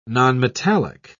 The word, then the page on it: nonmetallic: British English